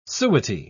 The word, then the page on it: suety: British English